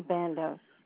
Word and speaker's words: Bandos: Bandos. – Spell that name, please. – Janice, and middle initial is E, and Bandos, musical band, BANDOS.